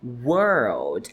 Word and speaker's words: World: E isso, a pronúncia correta da palavra mundo. World. É como se tivesse duas palavras dentro de uma. World. Porque senão world. Você tá falando palavra, né?